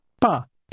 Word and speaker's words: 吧: The ba at the end of that sentence is pronounced with a falling tone, and it means, let's say, say, in that case, let's say 7 o'clock.